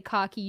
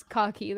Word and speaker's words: khaki: we say khaki. Not cocky. Cocky. – Okay. – Nobody says that. – Okay. Well, it's cocky. That's how it's pronounced.